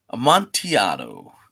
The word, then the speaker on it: Amontillado: The Cask of Amontillado. Did I say that right? – Amontillado.